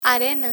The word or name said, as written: arena